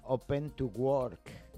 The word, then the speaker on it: Open to Work: Bueno, en fin, vamos a hablar luego de esto que se utiliza en LinkedIn, que se llama Open to Work.